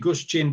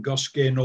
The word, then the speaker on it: Guscin: It's it's a Polish surname. Okay, it should be Guscin, but I'm used to it being Goskin or whatever.